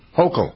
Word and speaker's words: Hochul: Was Governor Hochul, is that how you pronounce her name? Was she a lawyer before? – Hochul.